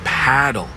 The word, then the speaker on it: Padel: No, that's paddle. Rhymes with saddle.